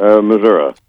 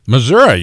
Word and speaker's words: Missouri: You heard this the way he said it. It's Missouri, not Missouri. Okay. – Yeah, people from Missouri say Missouri. Okay. If they come from Missouri and pronounce it Missouri, they're putting on airs.